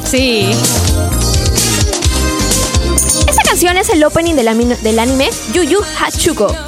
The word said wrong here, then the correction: Yu Yu Hakusho: ¿Lo pronuncié bien, Jorge? – No, Yu Yu Hakusho.